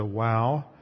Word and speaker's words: vav: And in fact, I think a lot of students pronounce it as a wow. And yet I was taught a more of a German pronunciation where we pronounced it as a vav, which shows the pronouncing the W as if it's a V.